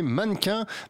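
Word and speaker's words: MNNQNS: Un titre du groupe rouennais Mannequin, mais ça s'écrit sans voyelle, donc M-N-N-Q-N-S. M-N-N-Q-N-S.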